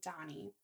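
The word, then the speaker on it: Dhani: It's D-H-A-N-I. So I'm thinking Donnie.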